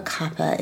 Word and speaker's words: kapha: Now, do you say kapha or kapha in Ayurveda? – I was taught technically it's kapha. – Kapha. Okay. Mm-hmm. It's spelled K-A-P-H-A.